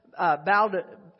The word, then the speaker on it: Baudelaire: Charles Baudelaire, that's how you say his name, knows what he said.